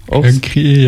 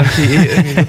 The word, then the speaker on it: Cancri e: Und zwar ist es ein Exoplanet, der 55 Cancri E. Oder wie würdest du ihn aussprechen? – Cancri E, ja. Cancri E.